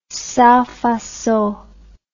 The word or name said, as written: sans facon